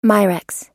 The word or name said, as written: mirex